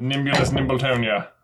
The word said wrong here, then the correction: Mimbulus Mimbletonia: Or Mimbulus Mimbletonia, whatever. – Yeah, Mimbulus Mimbletonia. – You did both spell it wrong, but it is Mimbulus Mimbletonia.